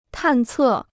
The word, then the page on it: 探测: tàn cè